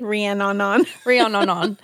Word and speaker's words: Rhiannon: Rhiannon.